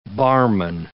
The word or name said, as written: barman